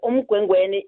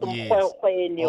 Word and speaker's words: Umgwengweni: And Umgwengweni is spelled as in U-M-G-W-E-N-G-W-E-N-I-E. Umgwengweni. You see, not mkwekweni. – Oh, you don't say mkwekweni, yes. – No, not mkwekweni, as the Sotho languages would say.